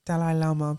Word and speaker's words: Dalai Lama: Dalai Lama, but...